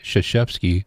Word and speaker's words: Krzyzewski: Mike Krzyzewski, the Duke basketball coach, one of the greats of all time, 78 today, coached Olympic basketball also. Not pronounced as spelled, Mike Krzyzewski.